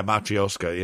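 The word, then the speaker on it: Matryoshka: And I know we'll get into, is it Matri- how do you say it Matroska uh, later on in the in the season? Uh, Ma- yeah, Matryoska.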